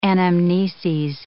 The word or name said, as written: anamneses